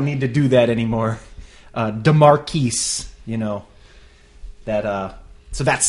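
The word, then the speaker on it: De'Marquise: D E apostrophe M-A-R-Q-U-I-S-E Eakins. – DeMarcus Eakins. – I believe is the gentleman's name. So I mean that sounds French to me. – I believe it's DeMarquise.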